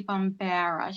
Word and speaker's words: Bombara: Please, let's welcome to the show, Laurie Bombara.